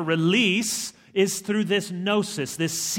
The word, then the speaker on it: gnosis: Comes from the Greek word gnosis, which has a silent G, just to be tricky, G-N-O-S-I-S.